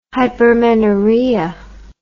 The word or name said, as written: hypermenorrhea